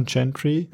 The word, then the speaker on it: Gentri: Das Lied ist von Gentry, G-E-N-T-R-I. Ich nehme an, die spricht man Gentry.